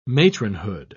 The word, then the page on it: matronhood: British English